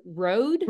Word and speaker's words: Rode: So Mike's, how do you say R-O-D-E? Is it road? – Road. Yeah.